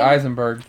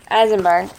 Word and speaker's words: Eisenberg: Eisenberg. – Eisenberg, with him in it with American Ultra.